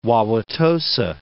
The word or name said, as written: Wauwatosa